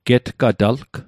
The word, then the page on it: getgatalg: Mi'gmaq